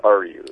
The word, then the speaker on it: Ariu: Did I get the pronunciation of Ariu right there? Is it Johan and Ariu? Or is it are you? Is it quite literally are you, like are you C-3 or whatever? Or is it are you? – It's more are you.